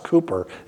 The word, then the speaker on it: Cowper: It's pronounced Cooper. It looks like Cowper, but in 1774, he wrote this hymn.